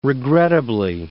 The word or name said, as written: regrettably